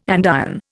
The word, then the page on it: andiron: Indian